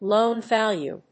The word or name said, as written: loan value